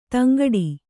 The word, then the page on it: ತಂಗಡಿ: taŋgaḍi